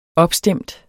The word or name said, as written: opstemt